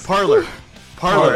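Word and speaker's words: Parler: I'll make us a parlay account, and we're gonna be the most hated account on parlay. – Yeah, parlor.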